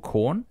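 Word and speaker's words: Quorn: but it's called corn. It's made of corn. It's spelt with a Q.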